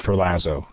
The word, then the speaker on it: Ferlazzo: Well, I suspect that that is actually the accurate Italian pronunciation. I use Ferlazzo.